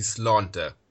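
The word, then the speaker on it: sláinte: The Irish word for health is actually slánta. – What does that mean? – It means health. It's spelled S-L-A-I-N-T-E.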